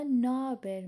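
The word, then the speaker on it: naber: دوستان یک چیزی راجع به نهابر میخوام بهتون بگم. نهابر هم مثل ناسلسن. ترک ها وقتی تند تلفظ میکنن نهابر رو شما نابر میشنوید. بعضی وقتا تو نوشتار هم مینویسن نابر.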